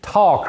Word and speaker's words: tau: It's called a tall cross, T-A-U is the way it's pronounced, and you can find examples of it in the Bible.